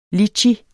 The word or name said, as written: litchi